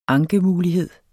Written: ankemulighed

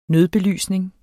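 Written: nødbelysning

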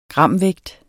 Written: gramvægt

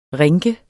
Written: rinke

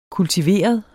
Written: kultiveret

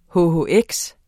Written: hhx

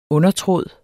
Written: undertråd

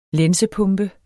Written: lænsepumpe

